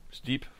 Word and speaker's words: Steep: Steep vorgestellt.